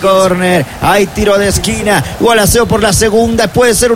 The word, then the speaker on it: Gualaceo: Segundo tiro de esquina del partido, el segundo para Gualaceo, ya lo hará efectivo y se pronuncia Gualaceo ahí en el ataque, ¿no? – Sí, señor, hay que trabajar desde atrás.